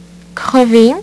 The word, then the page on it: crever: French